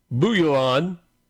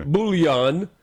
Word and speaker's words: Bouillon: Geoffrey of Bouillon. I didn't say that right. Bouillon.